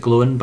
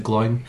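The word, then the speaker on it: Glóin: I think you're meant to pronounce it Glowin, but I always pronounce it Gloin.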